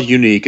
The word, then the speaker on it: Younique: So it's called Unique. It's spelled Younique.